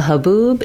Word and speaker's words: haboob: A haboob is an intense dust storm. The stress in the word haboob falls on the second syllable, boob. Haboob. – Haboob. – Letter A is unstressed and sounds like haboob.